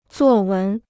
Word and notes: 作文: zuò wén

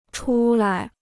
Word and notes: 出来: chu lai

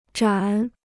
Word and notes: 盏: zhǎn

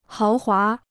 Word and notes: 豪华: háo huá

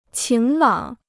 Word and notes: 晴朗: qíng lǎng

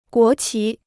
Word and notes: 国旗: guó qí

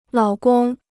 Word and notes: 老公: lǎo gong

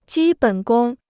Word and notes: 基本功: jī běn gōng

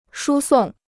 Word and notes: 输送: shū sòng

